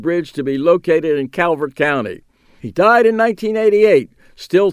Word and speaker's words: Calvert: Louis was comptroller of Maryland and an unapologetic, unrepentant booster of Calvert County. He pronounced it Culver County, where he was born and raised and developed his political muscle.